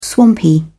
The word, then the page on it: swampy: British English